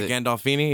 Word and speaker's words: Gandolfini: And I bring it up because you told me it's James Gandolfini. Is that how you say his name? – Yeah, Gandolfini. Yeah, yeah.